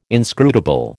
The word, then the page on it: inscrutable: American English